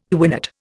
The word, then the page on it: quinate: Indian